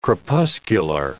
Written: crepuscular

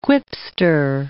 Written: quipster

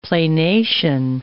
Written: planation

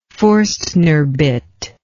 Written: Foerstner bit

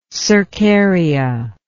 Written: cercarian

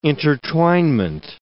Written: intertwinement

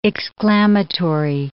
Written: exclamatory

